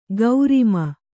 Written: ಗೌರಿಮ

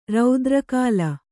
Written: ರೌದ್ರಕಾಲ

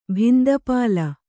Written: ಭಿಂದಪಾಲ

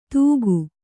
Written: ದೂಗು

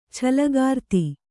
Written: ಚಲ್ಲಕಾರ್ತಿ

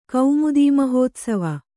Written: ಕೌಮುದೀಮಹೋತ್ಸವ

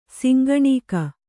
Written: ಶಂಖನಖ

